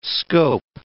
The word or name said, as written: scope